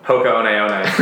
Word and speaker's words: Hoka One One: Hoka One One.